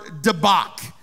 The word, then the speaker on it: dabak: And the Hebrew word for united is the word dabak. Dabak. D-A-B-A-K.